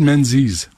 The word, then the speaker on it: Menzies: Et pendant ce temps, The Rebel News, un gars qui s'appelle David Menzies, comme ça qu'on prononce.